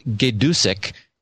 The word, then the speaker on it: Gaydusek: Uh, I believe Jim's last name is pronounced Gaydusek, G A Y D U S E K.